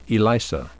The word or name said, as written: ELISA